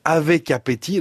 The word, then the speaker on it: Avekapeti: Avec Appétit, alors ça s'écrit comme ça se prononce, AVEKAPETI, a déjà fait saliver les investisseurs qui ont parié 240 000 euros sur l'affaire.